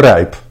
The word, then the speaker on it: Rijp: That is the Dutch I. So you pronounce it as ripe.